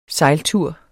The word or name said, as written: sejltur